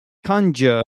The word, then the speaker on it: conjure: Okay, apparently it's pronounced conjure. I said conjure, so conjure. And it means to make something appear by magic or as if by magic. Interesting. To make something appear by magic or as if by magic. Conjure. So it's not conjure, it's conjure as well. So the stress is, Zdenek and listeners, on the first syllable.